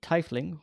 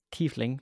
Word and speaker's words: tiefling: So my character is a tiefling, or tiefling, depending on your pronunciation of the word.